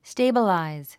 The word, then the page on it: stabilize: stéibəlàiz; ステェイバラァイズ